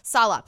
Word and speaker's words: Saleh: I'm probably going to mispronounce his name, but Robert Saleh? – Saleh.